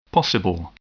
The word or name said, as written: possible